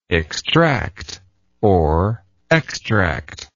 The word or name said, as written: extractibility